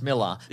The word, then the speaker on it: Millar: I know it is pronounced Miller, but we've said Millar too many times to go back now.